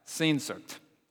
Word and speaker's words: Sehnsucht: It's actually a German word that Google told me is pronounced something like that, Sehnsucht.